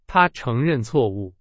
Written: 承认

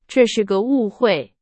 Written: 误会

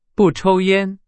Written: 烟

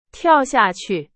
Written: 下去

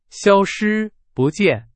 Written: 消失

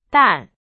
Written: 淡

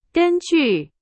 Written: 根据

